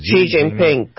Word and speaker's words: Xi Jinping: Xi Jinping, correct.